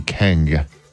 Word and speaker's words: Kheng: K-H-E-N-G. Keng.